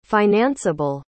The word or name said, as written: financeable